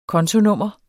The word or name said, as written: kontonummer